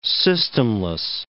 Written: systemless

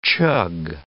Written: chug

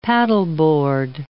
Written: paddleboard

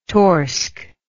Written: torsk

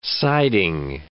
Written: siding

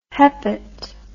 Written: hepat

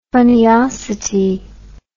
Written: funniosity

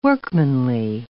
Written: workmanly

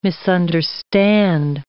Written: misunderstand

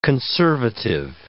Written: conservative

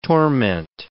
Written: torment

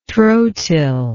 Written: trotyl

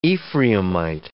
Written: Ephraimite

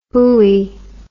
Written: hui

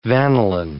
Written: vanillin

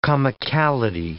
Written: comicality

